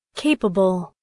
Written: capable

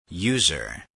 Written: user

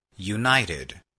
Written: united

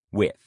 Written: with

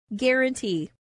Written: guarantee

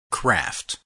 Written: craft

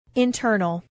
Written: internal

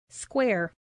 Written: square

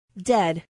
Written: dead

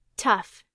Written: tough

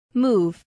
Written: move

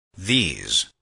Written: these

